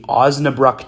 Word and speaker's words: Osnabrück: And, you know, what do you kind of, you know, remember most about playing for the – is it the Osnabrück Tigers? Did I pronounce that right? – Nailed it.